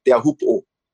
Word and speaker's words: Teahupo’o: I go surf Teahupo’o. That's the real pronunciation, you know.